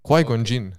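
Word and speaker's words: Qui-Gon Jinn: ampak to je izjavil Kwaj Gonjin. – Kwaj Gonjin, okay.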